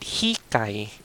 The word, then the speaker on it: hikay: We call it hikay.